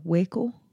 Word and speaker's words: Waco: I started watching Wacko. No, it's not Wacko. Waco.